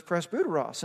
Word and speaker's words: presbyteros: You actually pronounce that Y as a U in Greek, and so it's presbyteros, and that's in verse 1.